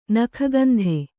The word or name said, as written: ನಿಗ್ಗಂಥ